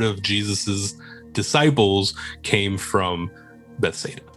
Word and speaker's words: Bethsaida: Then you have Capernaum, and then Bethesda, right? – Yeah, Bethsaida. – Oh, is that how you say it? Bethsaida? – Yeah. Bethsaida or Bethsaida.